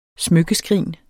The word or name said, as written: smykkeskrin